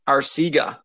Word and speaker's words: Arcega: JJ Arcega Whiteside. – Arcega. – Arcega.